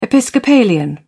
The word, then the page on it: episcopalian: British English